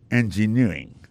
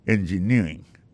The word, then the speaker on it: engineering: Electrical engineering. Engineering, ça, l'accent tombé le même sur avant dernier syllabe là.